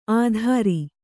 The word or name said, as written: ಅದ್ರಿ